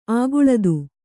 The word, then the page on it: ಅಕುಳಿತ: ākuḷita